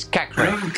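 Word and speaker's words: Caqueret: In French, when you have a T at the end and you don't have anything behind and you have only one T, you don't pronounce it. So it's Cacré.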